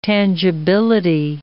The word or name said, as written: tangibility